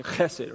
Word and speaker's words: chesed: If you get a little like a, you know, fur ball or something going on, you chesed, right? It's kind of a CH sound at the beginning of it.